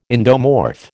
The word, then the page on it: endomorph: American English